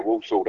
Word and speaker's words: Walsall: Walsall, that's it, mate.